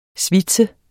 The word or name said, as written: svitse